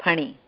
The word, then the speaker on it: Phani: And so my husband's request was that not to change my name too much, and so Guru Maharaj gave me Pani Sri Lakshmi. That was okay. – But your given name, Pani, was that Fanny? – No. It's Phani. The H is silent. It's Pani.